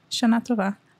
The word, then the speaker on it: Shana Tova: Because I always say Shana Tova, but I don't know that I'm saying it correctly. – That's how you say it. Shana Tova.